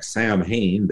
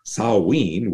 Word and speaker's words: Samhain: We look at it, looks like Samhain. They pronounce it as Samhain,